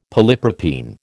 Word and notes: polypropene: American English

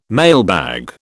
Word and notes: mailbag: British English

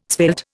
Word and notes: svelte: Indian

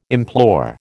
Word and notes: implore: American English

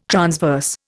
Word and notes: transverse: Indian

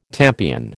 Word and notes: tampion: American English

